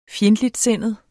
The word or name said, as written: FJENDTLIGTSINDET